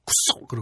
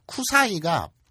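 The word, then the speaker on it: 쿠사이가 (臭い): 그래서 그걸 딱 아, 쿠쏙 그러거든요. 쿠사이가 냄새 나쁜 냄새가 난다, 나쁜 냄새가 난다라는 뜻인데 쿠사이를 되게 세게 발음해서 쿠사 쿠쏙 그러거든요.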